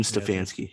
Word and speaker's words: Stefanski: Kevin Stefanski.